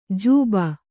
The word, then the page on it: ಜಬ್ಬು: kannada